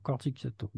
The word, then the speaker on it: Corticchiato: Comment ça se prononce pour pas faire de bêtises Corticiato, comme s'il n'y avait que des cas. – D'accord, Corticiato.